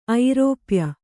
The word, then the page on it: ಐರೋಪ್ಯ: airōpya